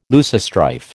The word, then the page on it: loosestrife: American English